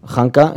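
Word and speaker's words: Khanka: Hanka se escribe como se dice, solo que con K, en plan un poco anarca.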